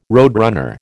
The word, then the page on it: roadrunner: American English